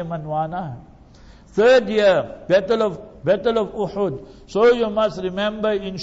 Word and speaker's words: Uhud: If there was a person who played a prominent role in the Battle of Uhud, Jabalu Uhudin yuhibbuna wa nuhibbuhu. Remember his hadith in Bukhari Sharif. Learn to pronounce the words properly. It's Uhud. Jabalu Uhudin with a dhamma on the ha, a pesh on the ha.